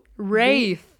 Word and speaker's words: wraith: Es wird irgendwie Wraith. Wraith? Also Wraith. W-R-A-I-T-H. Wraith. Ja. Wraith ist es.